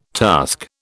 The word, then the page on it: task: British English